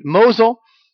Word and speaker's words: Mosul: Mosul, had it on the tip of my tongue.